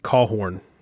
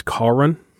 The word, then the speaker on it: Cawron: We pronounce it Cawhorn. It's said a little differently than it's spelled. Technically, it's Cawron, like C-A-W-R-O-N. Okay. Is how you technically pronounce it, but we've always just pronounced it Cawhorn. So we've pronounced it wrong in our entire lives. Okay. And just left it.